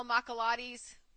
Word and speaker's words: macchiato: This caramel macchiato, that's how you pronounce it, from Starbucks, which I love.